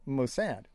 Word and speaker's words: Mossad: Contradiction. No, it's Mossad.